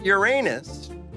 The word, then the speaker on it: Uranus: The planet Uranus, and yes, that is the proper pronunciation.